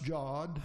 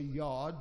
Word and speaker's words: Jod: We start with a J at the top, pronounced Jod. Some will say Yod because the Y and the J interchange in sound, but Jod.